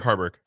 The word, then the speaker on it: Haarberg: Heinrich Haarberg.